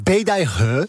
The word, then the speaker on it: Beidaihe: Het is Beidaihe.